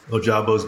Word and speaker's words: Ojabo: We'll talk about the reaction, guys, you can tell me how to say the name correctly. Michigan DN, David Ojabo. – Ojabo's good.